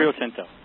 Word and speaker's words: Rio Tinto: Richard Ellis, Rio Tinto. Did I say that right? Rio Tinto.